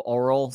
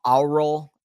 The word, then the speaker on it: aural: And I realize that I've got some sort of oral sensitivity that may be... – Some oral sensitivity? – Aural, A-U-R-A-L. I hate pronouncing that word because it sounds like oral, but it's aural.